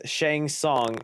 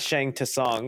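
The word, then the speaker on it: Shang Tsung: Uh, okay, so I heard it's Shang Song, not Shang to Song.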